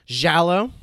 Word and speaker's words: giallo: so uh, this is these films are considered uh, giallo. I don't know if I'm pronouncing that right, mm-hmm. but that's giallo. Uh, giallo. Yep.